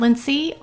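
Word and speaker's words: Lincee: You can find me at Lindsay on Twitter and at Lindsay Ray on Instagram and Facebook.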